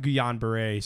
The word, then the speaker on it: Guillain-Barré: And uh, even though the doctors later confirmed that Jameson doesn't have Guillain-Barré syndrome, did I say that right? – Yeah.